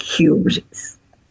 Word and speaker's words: hubris: Se escribe H-hubris y se dice hubris, que significa orgullo desmedido o insolente desafío.